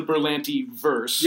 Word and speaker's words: Berlanti: Berlanti verse. – Yes. – Is that, am I pronouncing that correctly? – Sure. Sounds good.